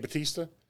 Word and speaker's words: Bautista: Dave Bautista, is that right? – Dave Bautista.